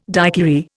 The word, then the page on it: daiquiri: Indian